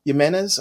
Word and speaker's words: Jimenez: Jimenez.